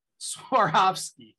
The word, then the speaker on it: Swarovski: How do you pronounce that? Sarkozy? Sarkozy? – Swarovski. – So I always get it wrong.